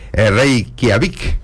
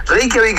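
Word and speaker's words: Reykjavík: Bueno, bienvenido a nuestra gente y explícame un poquito qué es esto de Reykjavik. ¿Digo bien? – Sí, está muy bien pronunciado, sí. Reykjavik es la capital de Islandia.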